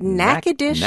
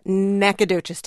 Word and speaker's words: Natchitoches: Natchitoches, Louisiana. Natchitoches, Texas.